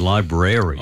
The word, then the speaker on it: library: The one thing you cannot do, which is banned absolutely and is verboten, is using the American pronunciation, which is library. – Oh, no. – You don't do rary. There's no rary in the middle of it.